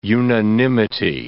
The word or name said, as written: unanimity